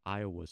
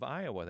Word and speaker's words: Iowa: The first word that I got wrong was the name of our state, because I'd always said it with two syllables, and I quickly learned that it should be said with three. – So give us the variations of Iowa there.